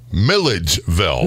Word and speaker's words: Milledgeville: I don't know why, but it's Midgeville. – No, Milledgeville. – Millage, like village, right? – You want to put the D's in first.